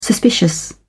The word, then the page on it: suspicious: British English